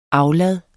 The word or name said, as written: aflad